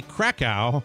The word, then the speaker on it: Krakow: Anyway, happy wedding day to James Mittman, his fiance, Sally Krakow. Yes, pronounced like the Polish city.